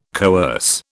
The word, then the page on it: coerce: British English